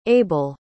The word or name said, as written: abell